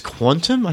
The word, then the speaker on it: Qtum: I think it's Quantum. I think that's how they want it to be pronounced.